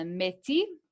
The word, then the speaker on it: Métis: Anyway, so the Inuit are the Arctic, and then the Métis. I'm only saying it like that because they say it's a French word, so I'm just going to pronounce it the French way.